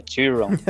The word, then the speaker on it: Theron: O pessoal fala que a pronúncia é Theron,